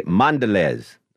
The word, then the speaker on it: Mondelez: Mondelez.